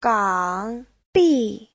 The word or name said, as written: gang3bi4